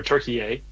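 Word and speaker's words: Türkiye: Turkey or Turkey A. Mm-hmm. As the proper pronunciation.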